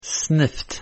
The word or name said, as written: sniffed